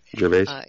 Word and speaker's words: Gervais: Gervais.